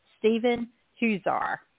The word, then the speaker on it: Stephen Huszar: We are so excited to bring you our special interview with the incredibly handsome and immensely talented Stephen Huszar.